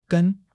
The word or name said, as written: gen1